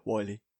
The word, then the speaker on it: Wily: I always used to think it was Willy when I was younger. I didn't pronounce it properly. I'm like, oh, Dr. Willy. No, Wily.